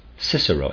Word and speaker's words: Cicero: We can call Cicero, Cicero, S-I-S-E-R-O, because in the Middle Ages it was still pronounced like that.